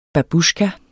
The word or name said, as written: babusjka